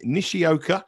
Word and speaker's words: Nishioka: Nishioka.